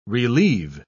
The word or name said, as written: relive